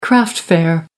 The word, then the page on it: craft fair: American English